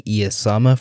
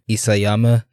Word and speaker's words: Isayama: No one actually told me, and that's not anyone's fault, but I have been saying Isayama's name wrong. I've been saying Iyasama for a long time throughout my analyses, and that is just incorrect. So obviously apologies to Isayama, the mangaka for Attack on Titan, obviously.